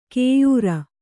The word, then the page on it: ಕೈಯಾರು: Kannada